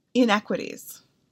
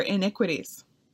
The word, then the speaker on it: iniquities: And I mispronounced inequities, for iniquities.